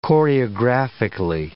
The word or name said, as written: choreographically